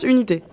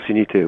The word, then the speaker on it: Unity: Oui, alors déjà, bon, parce que moi j'avais du mal justement, donc ça s'écrit UNT, mais UNT. Ça se prononce Unité. – Ça se prononce Unité, oui.